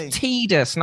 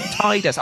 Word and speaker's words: Tidus: It's pronounced Tidus, not Tidus. Oh, come off it. – Anyway, it's definitely Tidus. – Do you know what? I reckon that the developer was having a laugh when he did that interview and just said, oh, it's pronounced Tidus. It's like, are you kidding me? Like, why? He's literally from the water, tide.